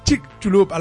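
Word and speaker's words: Chicxulub: Chic Chulup, a la pronúncia de mexicana de Yucatán, ¿vale?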